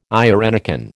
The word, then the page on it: irenicon: American English